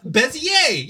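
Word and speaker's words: Bézier: Peter's look, is that how that's pronounced? A little bit of math, Bezier. Yeah, Bezier. – I always thought it was pronounced Bezier curves. – No, it's French. – It's French.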